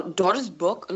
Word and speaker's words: Doris Burke: I follow Doris Burke a lot. I don't know if I've got the pronunciation of her name right. – Oh, it's perfect. – It's perfect, yeah.